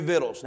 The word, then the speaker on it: victuals: Pass through the host and command the people, saying, prepare you victuals. Now, I know what your Bible says. It's got that C there. That C there is a silent C.